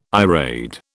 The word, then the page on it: irade: British English